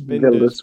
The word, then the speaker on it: Wilders: So Geert Wilders, I'm probably pronouncing that wrong, victory in Netherlands. – I think it's Binders.